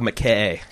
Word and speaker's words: McKean: Yeah, Michael McKean? I don't know how you say his name. – Michael McKay.